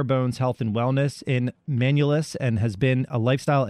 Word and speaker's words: Manlius: Manlius, newly functional nutrition. Did I say that right, Georgia? – It's Manlius. – Manlius, yeah.